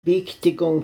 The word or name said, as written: Biigtigong